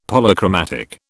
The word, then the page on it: Polychromatic: British English